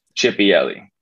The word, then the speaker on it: Chipielli: How do you say your last name, bro? – Chipielli. So Chipielli. – E-L-E. Chipielli.